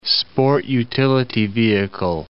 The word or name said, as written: sport-utility vehicle